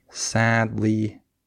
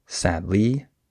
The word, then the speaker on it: sadly: So the way I would say that is I would stress the first syllable. I would have emphasis on sad, not on lee. I don't say sadly, I say sadly, right?